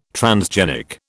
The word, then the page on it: transgenic: British English